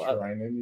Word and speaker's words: Trinan: Yeah, then you have, like, Trinan coming back. Mm-hmm. Like, you know, other little. – Trinan, you said it right this time.